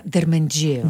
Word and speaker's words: Дерменджиева: На доктор Лидия Дерменджиева. – Да, Дерменджиева. – Дерменджиева. – Ударим ударението, да. Правилно.